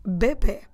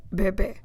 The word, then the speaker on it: bebê: We say o bebê. O bebê. Bebê, B-E-B-E com acento. So it's B-E-B-E with an accent on the last E to the right acute accent.